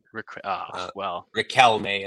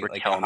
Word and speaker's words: Riquelme: You didn't nail it. – Riquelme. – It's like Riquelme. Like a hard C, hard K, whatever you want to say.